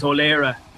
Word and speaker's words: O'Laoghaire: The surname that I usually go by is the Gaelic version of my name, so it's spelled differently to the one that you would see in most places. I think it's the one you emailed me is O'Leara, which is the Gaelic version of O'Leary.